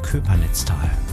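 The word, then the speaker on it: Köppernitztal: obwohl man es mit Doppel-P schreibt, ne? Eigentlich Köpernitztal, aber ausgesprochen Köpernitztal.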